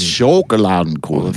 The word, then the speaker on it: Schokoladenkuchen: K-U-C-H-E-N. – There's an O in it? – Yeah, Schokolad. Yes, two O's. – Schokoladenkuchen. – Schokoladenkuchen. – So it's Schokoladenkuchen.